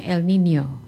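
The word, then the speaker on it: El Niño: Atau sering kita, kalau kita lihat tulisannya itu El Nino, tapi kalau bacanya El Nino.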